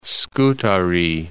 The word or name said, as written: Scutari, Lake